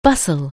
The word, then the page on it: bustle: anglais